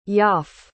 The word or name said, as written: yaff